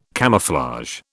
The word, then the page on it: camouflage: British English